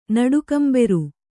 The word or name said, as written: ನಡುಕಂಬೆರು